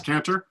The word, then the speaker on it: Cantor: I don't know if that was a accent or you just added a syllable to my last name, but that's cool. It's just Lucas Canter, which is cool.